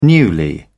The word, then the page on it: newly: British English